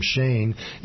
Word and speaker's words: Shane: The prettiest word is of course Shane, which Shane, my name Shane, in English rhymes with pain and insane, as any fifth grader will tell you.